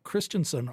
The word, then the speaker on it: Kristjansson: And my name is spelled Rob Christensen, RobKristjanssoN.